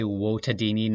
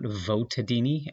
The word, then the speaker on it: Votadini: Yes, I did say Wotadini, not Votadini, as I lean toward the classical Latin pronunciation.